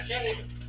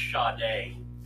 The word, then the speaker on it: Sade: You're shade. – No, it's pronounced Sade.